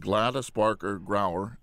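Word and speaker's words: Gladys Barker Grauer: And what I love about when I read the background of your film is the fact that being 95 years old, Gladys Barker Grower, am I saying her name correctly? – Mm-hmm. You are. – Yes.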